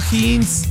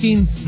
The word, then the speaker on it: Heinz: Jeans. – Tienes que moverla. – ¿Esta qué? – Ah, no es Heinz, ¿cómo se pronuncia? – Heinz.